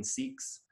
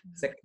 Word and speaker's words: Sikh: You see, I've, I've seen Sikhs, you know, with in a turban mm-hmm. and, and their uniform and that kind of thing. Excuse me, Sikhs. – Mm-hmm. – It's pronounced Sikh. Sick, sick, sick.